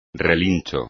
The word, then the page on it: relincho: espagnol